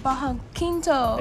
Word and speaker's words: bajo quinto: Can you pronounce it? Bajo quinto? – Bajo quinto. – There you go. – You got it.